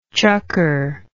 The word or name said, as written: chucker